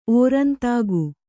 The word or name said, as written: ಓರಂತಾಗು